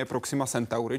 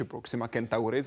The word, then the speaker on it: Proxima Centauri: Nejbližší hvězda planetě Zemi je Proxima Centauri či Proxima Centauri, záleží na tom, kdo preferuje jakou výslovnost.